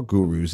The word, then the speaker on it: guru: I'm going to have to try Car Gurus in the future. – And I have to make a request. Please use the Alan Goodman pronunciation of guru.